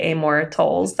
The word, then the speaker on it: Amor Towles: I always am self-conscious that I'm saying that incorrectly, but I think I've heard other people say Amor Towles. That's his name.